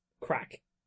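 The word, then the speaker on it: craic: So the first and most important is crack. So crack is not crack cocaine, although sometimes it is. But mostly... – May or may not be included in the when it's spelled C-R-A-I-C. C-R-A-I-C. – Yes. – C-R-A.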